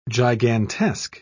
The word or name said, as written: gigantesque